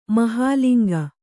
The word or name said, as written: ಮಹಾಲಿಂಗ